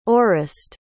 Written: orest

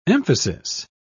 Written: emphasis

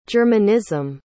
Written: germanism

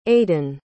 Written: aiden

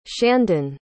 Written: shandon

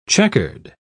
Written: checkered

